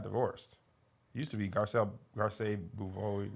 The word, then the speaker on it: Garcelle: Is it pronounced Garcelle? – Yeah. – She's Haitian. It might be French. – Yeah. – Garcelle. – We're going to say Garcelle for now.